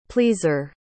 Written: pleaser

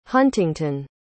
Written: huntington

